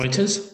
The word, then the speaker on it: Reuters: One of the last things I had for us today was some analysis from a company called Routers or Reuters. I have no idea how to pronounce Reuters. – It. Reuters, 100%, suggesting that Australia's red hot housing market will continue to boom.